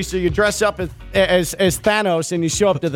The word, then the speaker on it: Thanos: The correct pronunciation is Thanos, I believe.